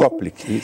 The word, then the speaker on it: Koplik: E muito se falou também sobre esse sinal de Koplik. É assim que se pronuncia mesmo? Koplik, isso.